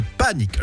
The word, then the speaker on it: panic: On dit, ah, la panique, non, là, c'est le panique, le panique. Ça s'écrit P-A-N-I-C.